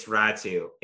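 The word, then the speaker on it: Räty: Is it Ratu or Ratty? – It's Ratu. It's R2 Ratu. – R-A-T-Y is Ratu. – It's the way it's pronounced in Finnish, man.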